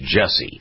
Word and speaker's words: Jussie: I never pronounced it. I say Jesse, but it's Jussie Smollett.